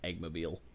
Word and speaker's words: Eggmobile: I am 98% sure it's pronounced Eggmobile.